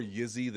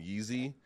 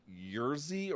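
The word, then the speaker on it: Jiří: It's more Yizzy than Yeezy, but apparently the R is almost like a ZR, so it's like Yerzy, or I can't do it.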